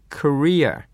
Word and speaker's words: career: まあ日本語ではキャリアという言い方をよくしますが、これ発音を間違えて、キャリアと言ってしまうとですね、運ぶ人という意味になってしまいますから、くれぐれも日本語のキャリアは英語では career、ね、後ろを強めに、発音する。そして最初の音はキャではなくてかですね、 career、 career center というのがある。